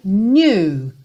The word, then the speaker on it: knew: I realized quickly when I knew. Knew, o K é mudo e tem um biquinho que é quase francês.